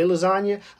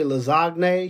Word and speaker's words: lasagne: You know, and it messed me up because I don't say lasagna. I say lasagne because it makes the most sense. I don't believe in silent letters. So I said lasagne. So the G in lasagne would not be silent.